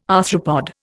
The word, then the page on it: arthropod: Indian